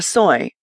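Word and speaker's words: soi: The little street that Nana Hotel was on was called a soy, pronounced like soy sauce.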